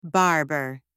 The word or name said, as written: barber